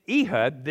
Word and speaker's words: Ehud: His name was pronounced correctly as Ehud, son of Gira.